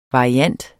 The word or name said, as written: variant